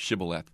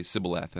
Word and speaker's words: shibboleth: They knew who they were by their inability to pronounce the word shibboleth. So they would say sibboleth.